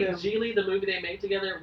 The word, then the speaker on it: Gigli: It's called Geely, but it's spelled G-I-G-L-I.